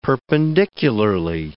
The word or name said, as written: perpendicularly